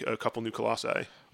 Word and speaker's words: Colossi: I don't know how to say it. – It's Colossi.